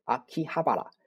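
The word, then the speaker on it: 秋葉原: 啊，阿基巴，阿基哈巴拉啊，然后呢，简称叫做阿基巴啊，A K I B A，阿基巴。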